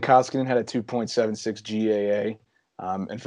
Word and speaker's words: Koskinen: So Koskinen, I believe how you pronounce it, right? – Yep.